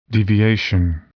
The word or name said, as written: deviation